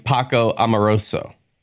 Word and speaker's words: Paco Amoroso: Catriol and Poco, how do I say it? Catriol and Paco Amoroso.